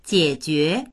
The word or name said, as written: jie3jue2